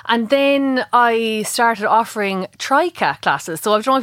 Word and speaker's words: Tryka: It's Trika. So T-R-Y-K-A.